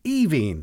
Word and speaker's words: Aoibheann: The second one is pronounced Evine. – Mm. – And I think it's because the E-A-N-N somehow affects the first five letters.